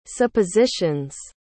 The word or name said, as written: suppositions